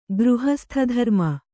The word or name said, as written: ಗೃಹಸ್ಥಧರ್ಮ